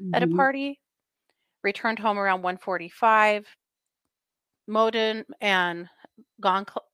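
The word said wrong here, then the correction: Gonçalves: every time I say it wrong, Goncalves.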